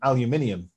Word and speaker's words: aluminium: Oh yeah, because we have aluminium over here, but you don't, do you? – No, and I can't even pronounce it.